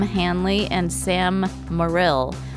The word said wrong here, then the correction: Morrill: You said it wrong, right? – Morrill, sorry. M-O-R-R-I-L.